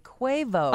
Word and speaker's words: Quavo: Quavo is actually pronounced like Quavo.